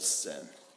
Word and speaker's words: Sin: It is in the eastern frontier of Egypt, and it's spelled S-I-N, but it's pronounced Sin.